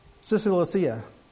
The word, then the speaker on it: Cilicia: Saul, or Paul as he will become known, had been busy throughout Syria and Cis- uh, I always get this name wrong. Cilicia.